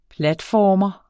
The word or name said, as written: platformer